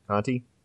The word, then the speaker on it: Conte: And it was thought up by a musician called Jack Conte, or C-O-N-T-E. Conte? Conte. Mm-hmm. – How do you spell that, by the way? – Jack, C-O-N-T-E.